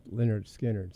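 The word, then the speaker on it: Lynyrd Skynyrd: Pronounced Leonard Skinner.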